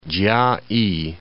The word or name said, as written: Chia-i